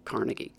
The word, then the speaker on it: Carnegie: So if you think of a word like carnival, Carnegie, carnival, or character with three syllables, where the default way to pronounce a word with three syllables, with certain exceptions, is to put the stress on the first syllable.